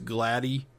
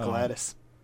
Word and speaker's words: Gladys: Is how it's spelled here pronounced Gladys? – Yeah. – Yeah. – So that's not short. That's not pronounced Gladdy, short for Gladys? – No. It's Gladys.